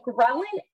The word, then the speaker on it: ghrelin: Is it called ghrelin? – Ghrelin.